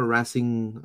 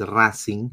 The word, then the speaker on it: Racing: both of them played in the same team before, Racing uh, Racing Club. That's how you will pronounce it in English, but it's Racing in Spanish.